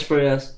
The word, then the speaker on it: Proyas: So the director Alex Proyas, I I, I don't know if I'm saying that right. – Yeah, Alex actually, Alex Proyas. Yeah.